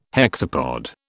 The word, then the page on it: hexapod: British English